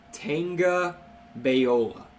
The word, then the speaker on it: Tagovailoa: I've heard Tagovailoa. I've heard recently that the correct way to pronounce it is Tagovailoa. Tagovailoa. Because it's Samoan.